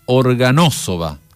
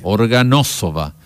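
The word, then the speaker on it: Orgonášová: Nos dice, Luba Orgonazova, se pronuncia Organosova. Uh-huh. La V debe ser también enfática. Organosova.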